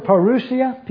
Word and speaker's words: parousia: The Greek word parousia, P-A-R-O-U-S-I-A, is pronounced as I just pronounced it, parousia.